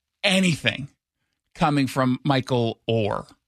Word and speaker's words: Oher: In fact, we forgot about Michael Oher. Is that how you say his name? – Or? – I like Oher. I'm just saying. – Okay. You asked me how to say it, and I told you.